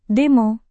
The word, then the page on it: demo: Indonesian